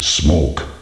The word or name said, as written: smoke